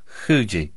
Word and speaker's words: Fuji: You pronounce it between an F and an H. You imagine that, pronouncing something between an F and an H. Wow. Fuji. Like that.